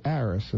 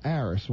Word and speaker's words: Aerus: They have to search for us under our new name, which is Aris, and that's spelled A-E-R-U-S like Sam. So we're now Aris.